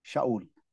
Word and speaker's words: Shaul: That is how it's pronounced, by the way, in Hebrew, Shaul. I will be the last Shaul.